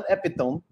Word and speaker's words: epitome: So I would say epitome until somebody corrected me and said, it's epitome, not epitome, epitome.